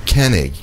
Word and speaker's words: Koenig: How do you pronounce Ryan's last name? – Koenig. – Koenig. K-O-E-N-I-G. – Koenig. – Koenig. – It means king in German.